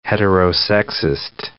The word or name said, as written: heterosexist